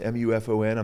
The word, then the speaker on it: MUFON: Was a couple of the UFO guys, you know, that were you know with with was a MUFON, M U F O N.